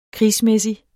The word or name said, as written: krigsmæssig